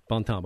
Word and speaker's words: Bontemps: We're talking about your former husband's grandma, identified as Grandma Alberta Johnson, and help me with the last name pronunciation. – Bontemps. – Okay, it is the French way.